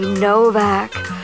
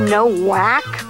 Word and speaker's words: Nowak: Oh, the best version ever of Psycho by Eddie Novak. Spelled N-O-W-A-K, and some bimbo once tried to argue the point is pronounced no-whack, but she's a type of gint you'd pronounce Sienkiewicz, Sienkiewicz.